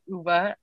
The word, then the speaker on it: Ove: So my next book was A Man Called Uva. I just looked it up. Apparently that's how it's pronounced, even though it's spelled O-V-E. But I am not Swedish, so.